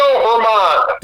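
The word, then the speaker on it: Germán: And then we get to Saturday, and Rocco Baldelli ends up getting tossed because Jermaine? The pitcher? – Domingo Herman. – Domingo Herman, thank you. I knew I wasn't going to be pronounced correct.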